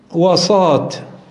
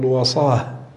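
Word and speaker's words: وصاة: ويقال أيضا وصات بتاء مربوطة، طبعا تاء مربوطة يعني فروض لا تنطق، يعني تنطق هاء، تقول وصاه، ولكن أنا نطقتها لتعلم، يعني يقال وصية ويقال أيضا وصات، يعني وصات أيضا مفرد وصايا.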